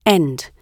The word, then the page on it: end: British English